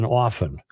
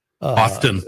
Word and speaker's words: often: By the way, I'm not sure where I stand on the use of the word on the sound of the T. In often. – Often. – Yeah. Often. Would you look that up, please? Get your pronouncer. – I believe is correct. – Really? – Yeah. When you escape a soft T guy.